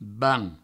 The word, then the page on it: banh: ban; Provençal